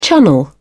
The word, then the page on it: chunnel: British English